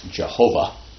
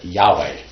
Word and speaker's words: Yahweh: It came across in earlier books, like earlier translations of the Bible, as Jehovah. And then a bunch of scholars did a lot of work and decided, no, that's not right. Let's pronounce it Yahweh.